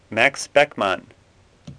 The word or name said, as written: Beckmann